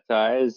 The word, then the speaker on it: Taiz: So you visited Taiz, is how you say it? – Yeah, Taiz.